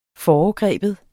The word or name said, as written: FOREGRIBE